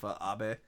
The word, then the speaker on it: Abe: Everyone's poking fun at your pronunciation of Abe. Abe. – Oh. – Shinzo Abe.